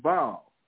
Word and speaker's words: Baal: And they, because the, uh, the Canaanites, they call them Baal, which is B-A-A-L, but the Armenians called him Bel, B-E-L.